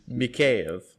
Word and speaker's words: Mikheyev: Mikheyev.